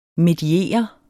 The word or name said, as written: mediere